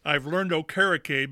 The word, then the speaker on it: Okereke: But so I've learned Okereke because it rhymes with O-carrot cake.